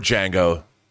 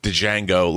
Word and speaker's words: Django: And that you have that, and you have our Django. The D is silent, you know, because I'm sure the joke would be everybody throughout history has always said Django, like Django Reinhardt.